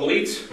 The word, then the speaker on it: Gleitz: My name is Paul Gleitz. My last name is spelled G-L-E-I-T-Z.